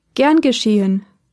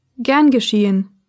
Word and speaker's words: gern geschehen: Another way of responding to a phrase of gratitude is gern geschehen, which means with pleasure. Gern geschehen.